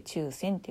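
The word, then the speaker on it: 注染: で、えー、浴衣はほの中には中線というもので染めらという手法で染められた浴衣もいっぱいあってこの中線っていう染め方は、えーとまあ、注ぐっていう字に染めるで中線って読む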